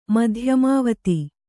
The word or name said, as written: ಮಧ್ಯಮಾವತಿ